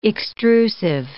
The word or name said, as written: extrusive